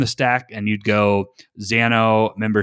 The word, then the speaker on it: Xano: There's a new kid on the block that is a backend as a service, and I believe you pronounce it Xano, X-A-N-O, and this is the first really no code, what I would call backend service.